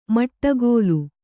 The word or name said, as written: ಮಡಗಾಲ್